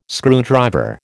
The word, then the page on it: screwdriver: American English